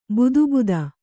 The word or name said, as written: ಬುದುಬುದ